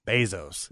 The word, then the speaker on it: Bezos: This Bezos, how do you pronounce his last name? – Bezos. Yeah.